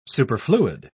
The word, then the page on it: superfluid: British English